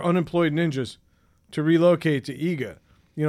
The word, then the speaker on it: Iga: Okay, as it turns out, a small Japanese city named Iga. I'm assuming I'm pronouncing that right.